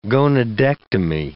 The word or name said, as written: gonadectomy